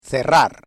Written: cerrar

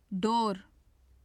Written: dor

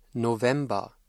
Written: November

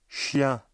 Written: chien

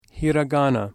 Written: hiragana

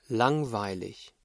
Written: langweilig

